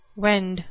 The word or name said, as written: wend